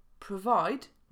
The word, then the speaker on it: provide: W słowie provide mamy provide. W zapisie jest o, ale wymawiamy schwa i provide, a nie provide.